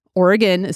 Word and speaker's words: Oregon: O-R-E-G-U-N, which is actually kind of funny because some people say Oregon, Oregon. It is Oregon spelled with an O at the end, but yes, it is pronounced with a U.